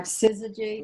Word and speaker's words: syzygy: It actually comes from a concept that Carl Jung introduced, the concept of syzygy, which we'll talk about in a minute. So Bill... Syzygy, not synergy.